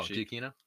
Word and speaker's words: Giacchino: Michael Ganoche. – Oh, Giacchino? – Yeah, thank you.